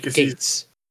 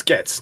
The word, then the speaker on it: Gaetz: It is Gates. – Gates. I told you. No, I thought it was Getz. No, it's Getz. Isn't it? – No, Gates. It's Gates. Like Bill Gates.